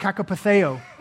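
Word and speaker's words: kakopatheo (κακοπαθέω): Well, that word for suffering, it's a Greek word and it's pronounced kakopatheo, and it means to endure evil, to endure hardships, to endure trouble times, to endure evil.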